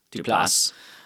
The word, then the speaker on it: Duplass: Uh, my understanding was that it was sort of to describe films that like the Duplass brothers, Duplass brothers, I forget how you say it, Duplass,